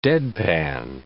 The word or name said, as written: deadpan